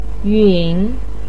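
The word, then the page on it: yun: tone 3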